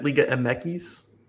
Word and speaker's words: Liga MX: We have lots of people excited about Liga M Equis. Is that how you say it? Liga M Equis? – Yes.